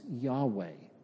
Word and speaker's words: Yahweh: and most scholars agree that the proper pronunciation is Yahweh.